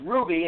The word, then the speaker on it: RWBY: And then you have another show, which is pronounced Ruby. It's spelled R-W-B-Y, and that's a very unique anime series.